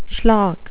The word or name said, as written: schlock